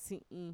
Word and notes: si'in: Mixtec

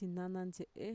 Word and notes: txinanantxe'e: Mixtec

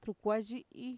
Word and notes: trukuayi'i: Mixtec

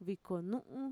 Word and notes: vikonu'un: Mixtec

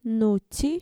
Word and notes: nutxi: Mixtec

